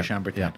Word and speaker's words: Chambertin: O-T-Y, which is a favorite of yours. – Absolutely. And then it was the Geoffrey Chambertin. – Chambertin.